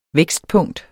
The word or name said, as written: vækstpunkt